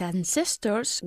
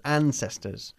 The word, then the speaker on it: ancestors: His ancestors were His ancestors, okay? We stress the first syllable. His ancestors.